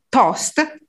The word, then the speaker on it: tost: We use the English word, pronounced in Italian, tost,